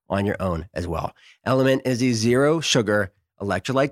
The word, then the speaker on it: LMNT: You can fix this so simply with just one packet of Element. It's spelled L-M-N-T.